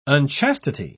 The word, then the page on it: unchastity: British English